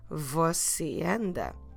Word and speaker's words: você: We'll do exactly the same with the você, because você, although it ends with the letter E, we can't drop it because of the circumflex there, so we need to pronounce it clearly. Você anda.